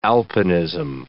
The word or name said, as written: alpinism